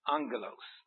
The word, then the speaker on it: angelos: Now then, you notice how I pronounce that? Angelos. The G preceding a guttural letter, that's a G, K, or a CH, is pronounced in Greek as an N, and it comes over into English as an N. And so, we have the word angel.